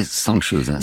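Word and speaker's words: Cansız: Janssöz, vous prononcez Janssöz. – Janssöz. – Et ça s'écrit C-A-N-S-I-Z. Alors, moi, j'ai prononcé à la française. – Bien sûr. – Hein. Mais ça se prononce comme ça.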